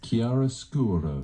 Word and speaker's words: chiaroscuro: Let's just see how it's pronounced. – Chiaroscuro. – Oh, no, you were right.